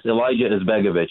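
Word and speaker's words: Alija Izetbegović: I butchered it. – Yes, Alijah Izbegovic.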